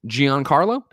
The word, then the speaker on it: Giancarlo: Breaking Bad's Giancarlo. Did I say that right? – I think you nailed it.